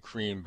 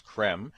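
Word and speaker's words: Creme: I've been saying cream, but it's Krem.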